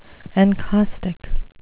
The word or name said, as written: encaustic